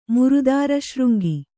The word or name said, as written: ಮುರುದಾರಶೃಂಗಿ